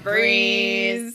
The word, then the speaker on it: Brees: with and You know- the sprees rhymes with our favorite dude, uh, Brees.